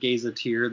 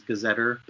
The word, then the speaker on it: gazetteer: Some some pronounce it gazetteer, the other is gazetter, but it's the uh, the map book that's produced by Delorme.